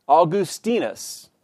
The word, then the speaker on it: Augustinus: Because it is a Latin name, the proper pronunciation is clear, Augustinus.